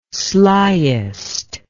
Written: sliest

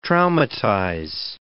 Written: traumatize

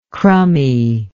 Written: crummie

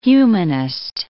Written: humanist

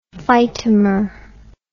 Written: phytomer

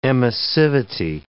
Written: emissivity

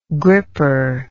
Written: gripper

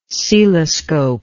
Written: celoscope

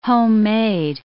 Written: homemade

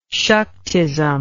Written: saktism

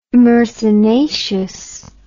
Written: myrsinaceous

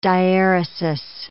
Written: dieresis

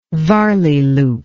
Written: Varley loop

